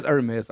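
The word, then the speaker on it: Hermès: Oh, it's Hermes. – Hermes. – Yeah, I'm a nigga, man. – Hermes. – It's Hermes, though. It is Hermes.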